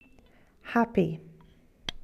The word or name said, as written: happy